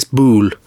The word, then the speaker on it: boule: You know the French boule where you throw the balls and hit the jack? – It's boule. – Yeah, boule. Sorry, did I say it wrong? – You said ball.